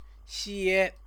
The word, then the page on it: xie: Chinese